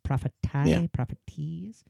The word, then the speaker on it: Prophéties: It's like prophet, but then it ends in IES. Prophéties.